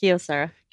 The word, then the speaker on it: Kyocera: Kyocera Wireless. Did I pronounce that right? No, how's that pronounced? – Kyocera.